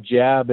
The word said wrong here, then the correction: Jabe: It's Jabe. – Jabe. Oh, sorry, Jabe.